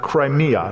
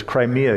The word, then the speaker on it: Crimea: But you've been hearing about the news about Russia's invasion of Crimea. That's how it's actually pronounced. That's the English pronunciation of it. It's Crimea.